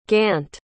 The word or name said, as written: gant